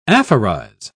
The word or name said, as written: aphorize